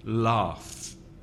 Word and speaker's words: laugh: And the next word, laugh, because the spelling is a bit tricky, a bit difficult. It's L-A-U-G-H. So the final 2 consonants have the F sound. So laugh. And the vowel sound, R, like car or far. So the pronunciation is laugh.